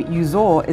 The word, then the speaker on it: Uzor: His first name, Uzor, is spelt U-Z-O-R, Uzor. And his last name, Nedekwu, is spelt N-D-E-K-W-U.